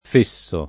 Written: fesso

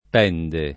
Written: Pende

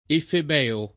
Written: efebeo

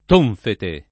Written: tonfete

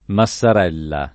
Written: Massarella

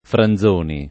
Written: Franzoni

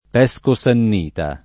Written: Pesco Sannita

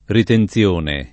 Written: ritenzione

